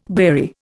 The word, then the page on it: beery: Indian